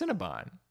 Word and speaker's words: Cinnabon: Well, I guess Cinnabon. Yeah. – Bon, not bun. Yeah. I mean, it makes sense. It's a B-O-N.